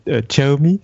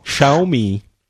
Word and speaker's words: Xiaomi: Euh, oui, donc j'ai acheté, oui, j'ai acheté mon Xiaomi, c'est comme ça qu'on prononce normalement. Xiaomi voilà, tu, tu arriveras mieux que moi.